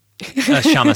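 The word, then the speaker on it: Kshama: And the K is silent. – A Kashama Sawant in Seattle.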